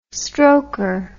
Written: stroker

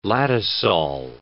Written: latosol

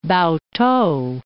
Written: Pao-t'ou

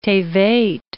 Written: Tebet

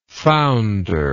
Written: foundership

